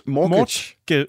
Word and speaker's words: Mortgage: Mort, jeg kan ikke engang udtale. Mortage? – Morg, mort, mortgage. – Mortgage. – Mortgage. – Mortgage. Ja. Teen Mortgage. Er det ikke sådan, man siger det? – Det er jo.